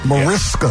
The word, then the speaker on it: Mariska: So Mariska Hargitay, is that how you say her name? – Mariska.